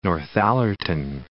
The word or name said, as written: Northallerton